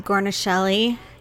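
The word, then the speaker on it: Guarnaschelli: Gornishelli.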